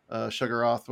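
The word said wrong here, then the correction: Shuma-Gorath: whatever. – Shumagoroth, there it is.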